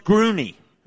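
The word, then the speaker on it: Gruene: No, that's pronounced Grooney.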